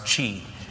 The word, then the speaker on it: Xi: But you know the new leader's name is Xi, pronounced Qi.